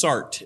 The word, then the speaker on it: Sartre: You might have thought it was Jean-Paul Sartre, like I thought my whole life, but it's Sartre. It rhymes with blart.